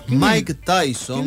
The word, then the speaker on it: Mike Tyson: Mike Tyson.